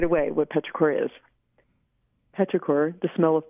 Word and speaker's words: petrichor: I wonder if you'd start with is it pronounced petrichor? – Yes.